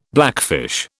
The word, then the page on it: blackfish: British English